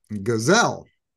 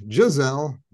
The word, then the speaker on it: Giselle: They talk about Leo's upcoming trip to New York and the possibility of running into his former dance partner, Gazelle, which I know it's pronounced Giselle, but I like to say Gazelle.